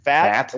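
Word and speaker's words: Pfaadt: Brandon, is it, you might know, is it with the P, is it a fat? Or, yeah, you don't pronounce the P.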